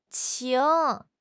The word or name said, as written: qing2